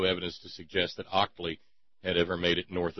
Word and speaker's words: Octli: It was called by them Octli, O-C-T-L-I. It is still consumed today in Mexico and is called in Spanish pulque, P-U-L-Q-U-E.